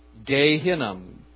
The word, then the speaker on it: Gehinnom: And then the word Hinnom, the valley of Hinnom, leaving out the sons in there, but just taking the valley of Hinnom, that would be pronounced Gehinnom.